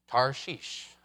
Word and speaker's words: Tarshish: He's got a one-way ticket to Tarshish. Tarshish. I mean, it rhymes with hashish for a good reason.